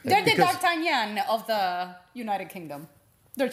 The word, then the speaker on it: D'Artagnan: It's the way you pronounce it. – That's how it's pronounced. – D'Artagnan. – In English.